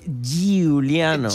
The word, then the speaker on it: Giuliano: Pero lo tenés que pronunciar así, Giuliano. – Giuliano. – Ajá. – Giuliano.